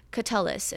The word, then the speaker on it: Catullus: but claiming she could recite the poems of Catullus in Latin.